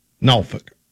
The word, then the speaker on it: Norfolk: The native pronunciation is... This is why I avoided it. Norfolk.